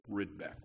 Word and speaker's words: Rydbeck: And my dad used to tell stories about those who would mispronounce our last name, Rydbeck. He would spell it R-Y-D as in Dallas, B as in Boston, E-C-K.